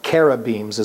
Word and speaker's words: cherubims: with cherubims, or carabims is the proper pronunciation, in Exodus 26, verses 1 to 10, and verses 31 to 37.